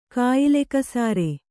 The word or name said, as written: ಕಾಯಿಲೆಕಸಾರೆ